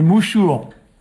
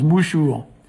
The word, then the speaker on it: mouchouer: C'est mouchour. Alors ça s'écrit mouchouer, mais ça se ici, dans notre région, ici, on prononce mouchour.